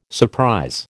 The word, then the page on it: surprise: American English